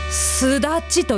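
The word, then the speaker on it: 月出里: これ皆さんなんて読むと思います？月出る里、すだちと読むそうです。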